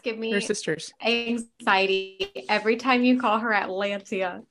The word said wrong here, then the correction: Atalantia: What's her name? People are gonna listen to this and be like, they're wrong. What's her name? How do you say it? Atalantia.